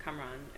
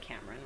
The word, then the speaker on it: Kamran: So Kamran is a Persian name, and so you can pronounce it Kamran is how you would pronounce it, or you can pronounce it Cameron, which is the Americanized version, but yeah.